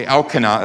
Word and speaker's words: Elkanah: Okay, Elkanah, that's how you pronounce that.